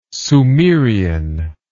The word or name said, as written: sumerian